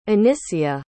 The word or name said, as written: Anisia